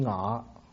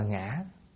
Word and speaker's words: ngã: Khi mà nói chuyện bình thường là xưng ngọ, ngọ với nị, lúc nói chuyện là ngọ với nị, nhưng mà khi viết ra chữ để đọc thì đọc là ngã.